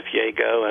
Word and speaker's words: Yago: It's just Jeff Yago. It's spelled Y-A-G-O.